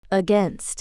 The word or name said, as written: against